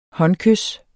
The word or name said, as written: håndkys